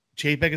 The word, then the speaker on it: Chapek: Chapek, is how you say it? – Yeah.